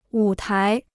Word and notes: 舞台: wǔ tái

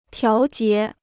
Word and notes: 调节: tiáo jié

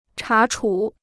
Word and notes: 查处: chá chǔ

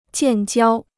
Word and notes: 建交: jiàn jiāo

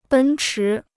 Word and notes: 奔驰: bēn chí

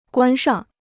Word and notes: 关上: guān shàng

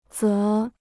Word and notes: 则: zé

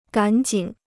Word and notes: 赶紧: gǎn jǐn

